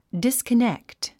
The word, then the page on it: disconnect: dìskənékt; ディスコネクト